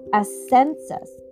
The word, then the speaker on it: Ascensus: Ascensus. A-S-C-E-N-S-U-S.